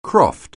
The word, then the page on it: croft: anglais